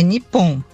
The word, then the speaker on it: Nippon: Como é que fala Japão? É Nihon mesmo ou não? Bom dia, minha amiga querida. – Marine, tudo bem? Ouvi tua pergunta agora. É Nippon, chama Nippon.